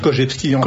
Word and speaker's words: Korzybski: On va dire que la coutume en France, c'est de dire Korzybski, mais ça se prononce Korzybski, paraît-il.